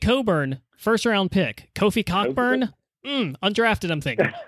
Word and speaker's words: Cockburn: Oh, I'm sure it says Coburn, but I'm also pretty sure that is a coordinated campaign to literally change how his last name is pronounced.